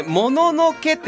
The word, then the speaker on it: もののけ: で、もののけってちゃんとあの発音するのはすごいんですよ、人はすごくて、だいたいアメリカではモノノキって言うんですよ。